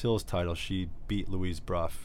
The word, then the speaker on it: Brough: Bruff. But, you know, spell that. Her name is spelled B-R-O-U-G-H. – Yeah, so Ruff with a B. – Yeah, so Bruff.